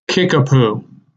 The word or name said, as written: Kickapoo